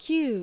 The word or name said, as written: Kyuu